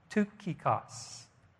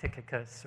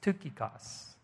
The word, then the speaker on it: Tychicus: It's pronounced Tukikos, this guy, not Tychicus or whatever. It's Tukikos.